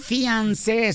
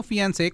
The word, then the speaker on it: Fiancé: Abogado, ¿cómo puede serle mi querida Leti para traer a su Fiancé, se dice así, ¿no? En inglés. – Es una palabra French.